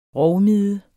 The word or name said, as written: rovmide